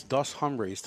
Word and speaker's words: Hombres: and it takes Dos Hombres to the next level. – I don't think you have to pronounce the H. I think it's just Dos Hombre.